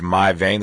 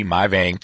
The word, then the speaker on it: Mai Vang: Her name again is My Vein. That's spelled M-A-I-V-A-N-G, My Vein.